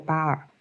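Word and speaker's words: Bhaer: 德语的这个巴尔发音和英文的空无一物是一样的。我们看到了一个已经没有灵魂的乔巴尔，或者说是一个魂穿了贝斯马奇的乔。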